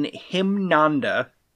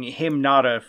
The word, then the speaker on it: Himnanda: Korean burger chain Himnanda, which I have been mispronouncing Himnada for a long time.